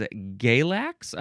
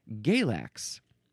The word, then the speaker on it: Galax: Next is that a long A? It is Galax, I think. Galax. G-A-L-A-X.